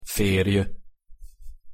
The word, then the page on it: ferj: Hungarian